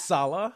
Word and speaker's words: Saleh: How do you say it? Saleh or Saleh? – Saleh.